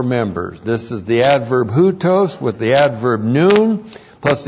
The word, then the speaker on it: houtos: First of all, houtos. Looks like that in the Greek, H-O-U-T, long O-S, houtos.